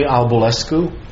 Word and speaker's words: Albulescu: B-O-R-G-E-R-S is my last name. – Shaista Ahmed, S-H-A-I-S-T-A-A-H-M-E-D with the FCIC. – Henry Albulescu, A-L-B-U-L-E-S-C-U, with Standard & Poor's. – Adam Zarofsky, Z-U-R-O-F-S-K-Y, Cahill Gordon. – Brian Markley, M-A-R-K-L-E-Y, Cahill Gordon, Council for Rescue.